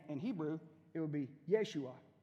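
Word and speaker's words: Yeshua (ישוע): The word there, the root word is the same as Yeshua. Excuse me, it's Yeshua. There you go. The emphasis is on the end of the word.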